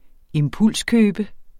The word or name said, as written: impulskøbe